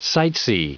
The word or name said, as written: sightsee